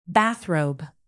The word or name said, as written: bathrobe